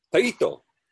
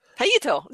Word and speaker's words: Taito: Taito. – Taito.